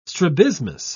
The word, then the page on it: strabismus: British English